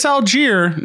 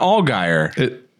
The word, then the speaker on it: Allgeier: And it is Allgeier, not Allgeier.